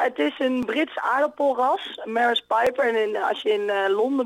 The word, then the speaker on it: Maris Piper: Maris Piper, zeg ik dat goed? – Ja, je zegt het goed inderdaad.